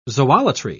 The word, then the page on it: zoolatry: British English